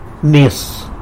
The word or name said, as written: nyis